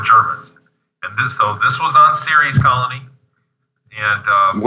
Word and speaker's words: Ceres: It's spelled Ceres. – Mm-hmm. – It's pronounced Ceres, if anybody wants to look up.